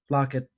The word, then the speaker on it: Laukat: Now, Above and Below is a two to four player game from Ryan Laukat, I think. – Lockett. Lockett. – Well, it looks like Laukat. Okay. ah, either way, either way. – Lockett is spelled like Lockett. This looks like Laukat.